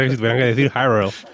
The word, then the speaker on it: Hyrule: O sea, Zelda dice Hyrule, como no lo voy a decir yo.